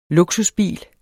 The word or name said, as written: luksusbil